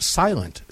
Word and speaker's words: SLNT: Make sure to check out their starter packs over there at SLNT, and it's pronounced Silent.